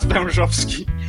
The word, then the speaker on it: Wężowski: It's pronounced Wężowski. And it means as a snake. Wąż is a snake and ski is just... We add that to everything and everyone. So yeah, Wężowski.